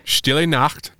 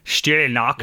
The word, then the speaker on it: Stille Nacht: Stille Nacht. – Stille Nacht.